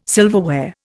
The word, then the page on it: silverware: Indian